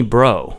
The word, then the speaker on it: Breau: And he played me this clip of Lenny Bro. It's spelled B-R-E-A-U.